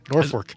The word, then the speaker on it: Norfolk: Especially the fact that it's spelled with an L but pronounced with an R. Norfolk.